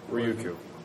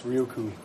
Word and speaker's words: Ryukyu: Ryukyu. – Yes, Ryukyu. – Ryukyu. I don't know if the first Y is silent. – Ryukyu. – Ryukyu? Is that what you looked up? – I don't know how to pronounce it. – Ryukyu. – I looked up how it was spelled. There are no I's in it. – Ryukyu. Let's go with Ryukyu.